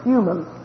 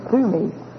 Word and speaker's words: Thummim: Some people pronounce them with an English pronunciation and say Urim and Thummim. But actually, they're Hebrew words, and they're Urim Thummim.